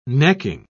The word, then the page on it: necking: British English